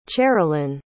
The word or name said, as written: cherilynn